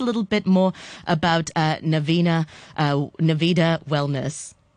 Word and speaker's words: Nevada: Just to correct the pronunciation of our center, it's Nevada. – Sorry, beg your pardon. Nevada, beg your pardon.